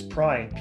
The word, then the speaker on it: Prine: It's Prine, P-R-I-N-E. Just Prine with an R.